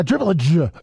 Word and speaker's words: dribblage: Yeah, a dribblage. – It's like somebody's hitting you in the gut on the last syllable.